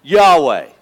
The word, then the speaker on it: Yahweh: Again, in Hebrew, God's name in Hebrew is Yahweh. We pronounce it Yahweh. It's actually spelled YHWH.